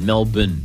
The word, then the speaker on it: Melbourne: She didn't say, most Americans say Melbourne. She said Melbourne. – I did notice that. Melbourne. Which is the Aussie way of saying it.